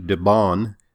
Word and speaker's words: DeBaun: DeBaun, that's spelled D-E-B-A-U-N, and he's got a great new book, Radiation Nation.